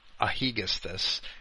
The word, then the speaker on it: Aegisthus: Agamemnon came home from, I think it was 11 years at the Trojan War, and Clytemnestra is shacked up with Aegisthus, I believe I should pronounce his name, Aegisthus, and they slay Agamemnon as he returns.